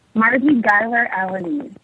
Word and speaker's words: Margie Giler Alanese: Margie Giler Alanese. – So it's Giler Alanese. So it's just exactly what it looks like.